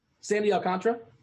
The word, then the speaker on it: Alcantara: like if you're looking at a guy like Alcantara, how'd you say his name? – Sandy Alcantara. – Alcantara. I never heard somebody say it before that way. – The booth says it, so I assume that's correct.